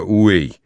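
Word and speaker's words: Huế: Джека Паттона застрелил снайпер в Хюе, произносится Уэй.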